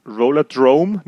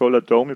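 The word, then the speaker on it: Rollerdrome: Du spielst Roller, es geht nicht um Roller Derby, sondern es geht eben um einen Roller Drome, nicht Roller Dome, ich habe das falsch gesagt.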